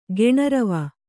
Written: ಘೋಣಾರವ

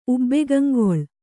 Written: ಉಬ್ಬೆಗಂಗೊಳ್

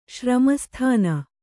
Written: ಶ್ರಮಸ್ಥಾನ

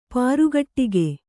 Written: ಪ್ರಕಟಗೈ

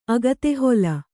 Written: ಅಗತೆಹೊಲ

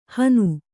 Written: ಹನು

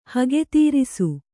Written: ಹಗೆತೀರಿಸು

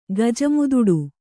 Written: ಗಜಮುದುಡು

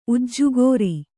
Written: ಉಜ್ಜುಗೋರಿ

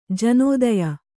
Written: ಜನೋದಯ

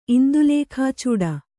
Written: ಇಂದುಲೇಖಾಚೂಡ

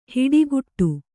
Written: ಹಿಟ್ಟುಗುಟ್ಟು

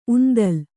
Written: ಉಂದಲು

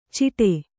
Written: ಚಿಟೆ

